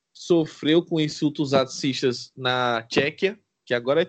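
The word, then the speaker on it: Tchéquia: Agora o, a pronúncia correta no português aqui no Brasil é Tchequia.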